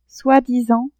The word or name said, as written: soi-disant